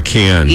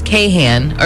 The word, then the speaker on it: Kahan: Yeah, they're spelling it K-A-H-N. Kahn. Yeah. – Okay. – Many people mistakenly pronounce it as Kahan or Kahan because of the spelling. – Yeah. – But he has confirmed that it's just Kahn.